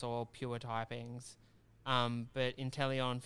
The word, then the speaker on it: Inteleon: Is it pronounced Inteleon? – Inteleon, yeah.